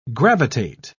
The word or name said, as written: gravitate